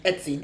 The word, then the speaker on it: Etsy: Did I say it right, Etsy. Etsy. Etsy. Mm-hmm. Yeah. yeah.